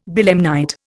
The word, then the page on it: belemnite: Indian